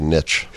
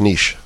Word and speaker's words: niche: And I think there's the Midwest and the nation, right, that needs to I love the fact that you actually pronounce that word right. Most people say niche. It's niche, right? – Okay.